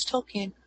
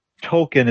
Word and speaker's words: Tolkien: For they had to remind him that he, it's not, they had to remind him it's not pronounced Tolkien, it's Tolkien.